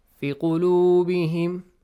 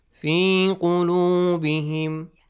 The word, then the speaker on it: في قلوبهم: في قلوبهم. Not في قلوبهم. Okay. في قلوبهم. Yeah, يمدي. Yes. في قلوبهم. في قلوبهم. If you say and not pronounce the ya, now pronounce the wow, it's wrong.